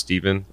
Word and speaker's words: Stepin: We found out it was a black actor from the 20s named, it's spelled S-T-E-P-I-N, but it's pronounced Stephen Fetchit, who said it in a lot of his movies where he was running away from cops.